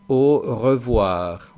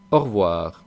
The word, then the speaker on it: au revoir: Ahí os he puesto que se pronuncia de dos maneras. Depende un poco de si estamos hablando rápido o si hablamos despacio y vocalizamos bien. En este último caso, que es el que vosotros deberíais empezar aprendiendo, es en la primera pronunciación que pongo, que es au revoir, au revoir. Y si hablamos rápido, au revoir.